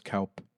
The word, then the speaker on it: coup: I think it's pronounced Kaup. – What? – I think it's pronounced Kaup. – Kaup? – Kaup. C-O-U-P. Kaup.